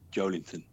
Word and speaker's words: Joelinton: I mean, I like the guy up front, Joelinton. Have I pronounced that right? – You have, yeah.